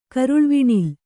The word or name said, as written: ಕರುಳ್ವಿಣಿಲ್